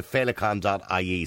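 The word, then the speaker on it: Feileacáin: So if people want to help out, by the way, they can go to felicon.ie. So now that's spelled F-E-I-L-E-A-C-A-I-N.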